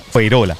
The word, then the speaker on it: Fuengirola: Decía Dani Rovira, se escribe Fuengirola, se pronuncia Fueirola.